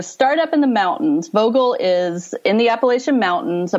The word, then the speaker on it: Vogel: All right, Vogel State Park.